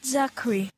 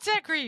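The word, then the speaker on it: Czuchry: Matt Zachary. – Thank you. Matt Zachary. So apparently the C is silent, much like in the word czar.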